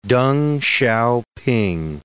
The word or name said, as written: Deng Xiaoping